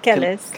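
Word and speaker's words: Kelis: Kellis.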